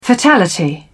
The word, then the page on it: fatality: British English